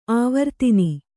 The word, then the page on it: ಆವರ್ತನಿ: āvartani